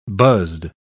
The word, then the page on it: buzzed: British English